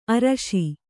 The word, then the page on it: ಆರ್ಷ: ārṣa